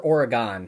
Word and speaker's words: Oregon: And is it Oregon or Oregon? Have I been saying it wrong my whole life? – It's Oregon. Like your organs.